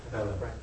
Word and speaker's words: Quelle: Quelle.